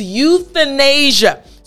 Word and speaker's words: euthanasia: Again, the word is euthanasia. So let's write it out. We have euthanasia. Yes, a very long word. But I want to go over the TH portion.